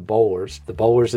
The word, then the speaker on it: Boulware: I always call the Hampton people, say, how do you say this? How do you guys say this down there? And so they, whatever they say back is what I end up calling them. So the Bowlers it is, but spelled like Bowler.